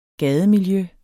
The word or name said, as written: gademiljø